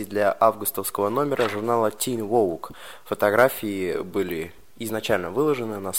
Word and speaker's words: Vogue: Эмма в журнале Teen, у меня плохо с английским, у меня маленький мозг, как это читается? Teen Vogue. – Vogue, да.